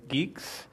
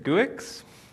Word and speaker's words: Guix: I would like to present to you my latest infatuation, my crush on GNU geeks. I'll start off with, it's kind of awkward calling it GNU geeks, especially when you're talking about crushes, but I, for the longest time, pronounced it GUIX.